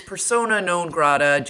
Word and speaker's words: persona non grata: Second of all, it's persona non grata. Do not pronounce it like potatoes au gratin.